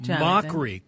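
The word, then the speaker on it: Mochrie: Then Colin McCree, is that what it is? Or McCry? – Mockery. Colin Mockery.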